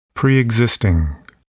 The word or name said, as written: preexisting